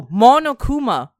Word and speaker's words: Monokuma: It's like Momofuka or something like that, but I feel like that's wrong. I think I butchered it. I'm sorry, everyone. – Okay. – It's Oh, Monokuma. Monokuma. Monokuma.